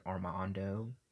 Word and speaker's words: Armando: And if you don't know my name is Armando.